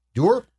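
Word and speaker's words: Dewar: Duer? Connor Duer, who is a fast... – So we pronounce it like Scotch whiskey, then.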